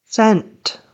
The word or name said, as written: sent